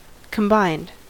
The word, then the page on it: combined: American English